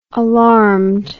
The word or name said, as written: alarmed